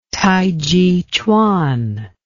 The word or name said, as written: tai chi chuan